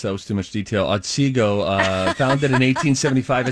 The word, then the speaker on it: Otsego: Well, my favorite part about Otsego is just pronouncing it like a Minnesotan. Otsego.